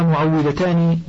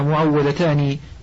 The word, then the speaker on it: معوذتان: والناس ينطقون الكلمة بالواو مشددة المفتوحة، وهذا خطأ، فكل واحدة منهما معوذة تعوذ الإنسان وتحميه، وهما معوذتان بواو مشددة مكسورة. وإذا كان لدينا طفلتان عوذناهما بالسورتين نقول عنهما إنهما معوذتان أي محصنتان.